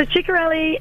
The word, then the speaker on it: Chikarelli: So Chikarelli is pronounced Chikarelli. – Chikarelli. – Chikarelli, because it is... you know, foreign. – Yeah. – It's not Chikarelli, it's Chikarelli. And he is my better half.